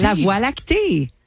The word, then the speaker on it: Voie Lactée: And I was like, bang, is it like La Voie Lactée? – Did you have any idea? Voie Lactée. – La Voie Lactée. – That's how you say it in French? – Yes, and I had no clue that it was called the Milky Way in English.